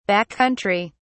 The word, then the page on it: back country: British English